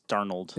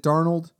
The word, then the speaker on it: Darnold: I think it's Darnold. – Is it Darnold? – Like Arnold with a D.